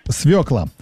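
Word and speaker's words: свёкла: Как правильно говорить? Свекла, свекла или допустимы оба варианта? – Оба варианта. – Свекла.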